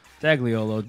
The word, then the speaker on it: Dagliolo: Dagliolo, D-A-G-L-I-O-L-O.